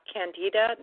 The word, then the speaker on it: candida: Yeah, and I was reading a little bit about candida. Am I saying it properly or correctly? – Yes.